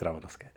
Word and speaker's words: Рамановская: Вот, так что, ну, ударение можно ставить по-разному, но правильно говорить Рамановская.